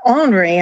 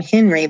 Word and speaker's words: Henri: Henri, and I love that you pronounced it correctly. I have to think about it sometimes and not call him Henry, but our captain, hero, Henri Lennox, is basically the prototype or the template for the privateers who eventually became the U.S.